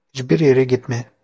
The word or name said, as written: gitme